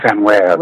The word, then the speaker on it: Samwell: Samwell.